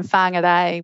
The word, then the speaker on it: Whangarei: Normally live in Whangarei.